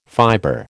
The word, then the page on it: fibre: American English